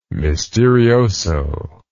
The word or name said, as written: misterioso